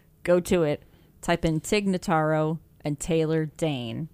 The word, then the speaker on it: Tig Notaro: Tig Notaro.